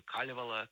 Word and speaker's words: Калевала: Калевала или Калевала, как говорят местные, с ударением на первом слог.